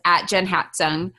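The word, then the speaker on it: JenHatzung: But so Instagram, my handle is @JenHatsung. – And spell that for them because it's a unusual spelling. – Yeah, so it's JenHat. Z as in zebra, UNG.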